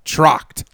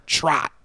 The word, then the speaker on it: Tracht: Trocht. – Okay. – T-R-A-C-H-T, like yacht, but with a T-R. Trot. Trocht.